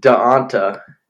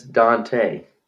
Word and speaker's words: D'Onta: And I thought it was Daonta, but it's Dante. – Oh, so we were both wrong? – No, you said Dante.